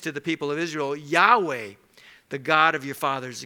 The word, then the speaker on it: Yahweh: What we know is that these are four Hebrew letters, and it's pronounced Yahweh, and in our English Bible, it is pronounced Lord, L-O-R-D, all caps.